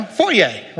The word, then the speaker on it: foyer: It's a French word. That's how it's pronounced, you know, foyer, right?